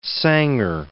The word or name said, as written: Sanger